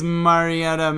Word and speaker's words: Mariota: Pulling a Roger Goodell on He's angling for NFL Mariotta. – At least I pronounced all the syllables. – Marcus Mariota, man.